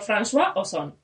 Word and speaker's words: François: Bueno, dirigido por, François Ozon.